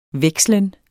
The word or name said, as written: vekslen